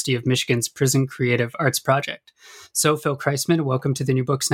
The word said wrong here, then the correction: Kreisman: It's Kreisman, the first syllable. – Oh, Kreisman.